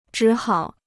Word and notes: 只好: zhǐ hǎo

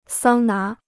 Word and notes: 桑拿: sāng ná

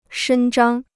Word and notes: 伸张: shēn zhāng

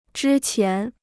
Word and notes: 之前: zhī qián

